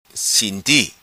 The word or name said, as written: Sinti